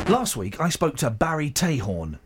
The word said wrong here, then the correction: Trahorn: Is it Tahorn or Trahorn? – Trahorn.